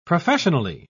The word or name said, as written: professionally